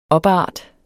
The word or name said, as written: OPART